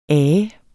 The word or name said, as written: AENDE